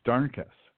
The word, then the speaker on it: Darnkess: And then a few videos pointed out that it's spelled, it sounds like darkness, but you switch the N and the K, and it's Darnkess.